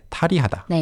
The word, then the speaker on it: 탈의하다: But the pronunciation naturally flows more like 탈의하다.